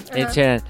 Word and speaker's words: Ed Sheeran: Ed Sheeran.